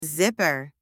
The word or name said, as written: zipper